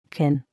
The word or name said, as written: kin